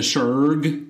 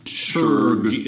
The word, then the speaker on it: Shurg: They're doing the Shurg. – The Shurg, which I thought was something like the shrug. You know? Yeah, but it's pronounced Shurg in the future.